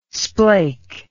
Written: splake

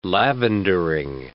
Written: lavendering

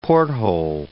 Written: porthole

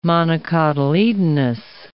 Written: monocotyledonous

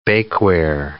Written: bakeware

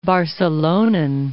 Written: Barcelonan

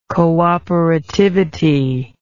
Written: cooperativity

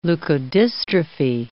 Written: leukodystrophy

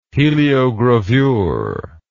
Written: heliogravure

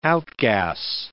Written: outgas

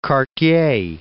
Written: Cartier

